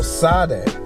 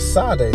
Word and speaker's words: Sade: No, S-A-D-E. – Oh, Sade. – It's supposed to be an accent on the top, but... – Yeah, but it's Sade, not with an H. I just say Sade.